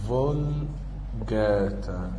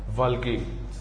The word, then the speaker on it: Vulgate: في بعض العلماء بيترجموها Vulgate او Vulgate او فولجاتا. يعني انت لو قريتها في اي مرجع مكتوبة باي طريقة مش مشكلة، هي المفروض نطقها السليم فولجيت، لكن معظم العلماء بيكتبوها في كتاباتهم الفولجاتا.